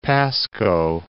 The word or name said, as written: Pasco